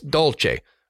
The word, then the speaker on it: dulce: I know the word is dulce. Dulce de leche. I know it's dulce. They pronounce it dulce. So that is why we're pronouncing it dulce.